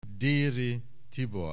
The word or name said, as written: Déry Tibor